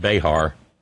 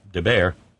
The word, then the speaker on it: Bexar: Bexar County in Texas is pronounced Bear, not Behar. Didn't know what to do with the X. It's a silent X, Texas T tells us.